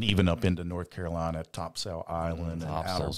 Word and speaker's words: Topsail: You got to say it like a native. – Topsail. – Topsail. – Yeah. Topsail. – Yeah. It's weird. – That's what they say. That's how they say it. Topsail. – Topsail. Yeah. It's like there's no A in it. It's just Topsail.